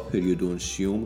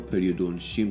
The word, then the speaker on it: پریودونشیوم: توی دنکست قبلی من بعضی جاها پریودونشیوم رو پریودونشیم تلفظ کردم که اشتباهه،